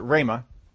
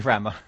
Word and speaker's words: Ramah: Now, Ramah, some people pronounce it Ramah, but it's not. It's actually Ramah.